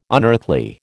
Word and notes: unearthly: American English

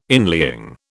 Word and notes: inlying: British English

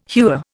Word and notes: hewer: Indian

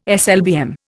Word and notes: SLBM: Indian

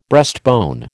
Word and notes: breastbone: American English

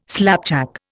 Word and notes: slapjack: Indian

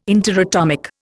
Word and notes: interatomic: Indian

